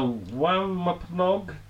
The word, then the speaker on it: Wampanoag: The Wampanoag demon? That's a Native American word I'm going to go with. – Yeah, very good pronunciation there.